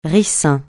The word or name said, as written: ricin